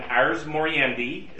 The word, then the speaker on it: Ars Moriendi: The actual shorter version of The Art of Dying, Ars Moriendi, is how it's pronounced is what it is in Latin.